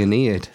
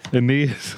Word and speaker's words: Aeneid: Virgil, the Aeneid. Aeneid. – The anal lead. It's the Aeneid. – Aeneid. – Aeneid, for fuck's sake.